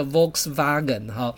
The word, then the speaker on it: Volkswagen: Diameter 就代表三公尺，这你有印象就可以了。我印象最深刻的是德语的发音实在太诡异了。比如说你们听过福斯汽车啊，福斯汽车的德语发音叫 Volkswagen， Volkswagen 原来它来自于这个 Volks 是人们，可是 Wagen 又是指车子，人们车，所以它叫大众车、国民车，希望的发音还算正确。